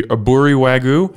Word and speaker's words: aburi wagyu: but the aburi wagyu? – Yeah, yeah.